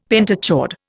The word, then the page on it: pentachord: Indian